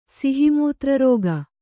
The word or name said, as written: ಸಿಹಿಮೂತ್ರರೋಗ